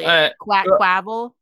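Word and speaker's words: Quaquaval: Oh, that's probably Quackquavel. That's almost certainly how it's supposed to be pronounced, knowing the Pokemon company.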